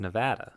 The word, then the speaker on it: Nevada: So then they told us how to say it, and it's Nevada. – Nevada. That's how the locals say Nevada. So make sure you say it with that A, because if you say Nevada, you're pretty much the equivalent of the scum of the earth to these people.